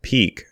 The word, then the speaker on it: P3K: The P3K, pronounced Peak, page was updated with a link to a new library from Aaron Parecki called Peak Micropub.